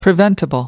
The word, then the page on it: preventable: American English